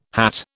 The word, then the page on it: hat: British English